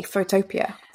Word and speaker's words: Photopea: Photo and then P-I-A, Photopea, that's how I pronounce it. – I think it's P-E-A. – P-E-A, Photopea.